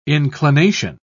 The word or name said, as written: inclination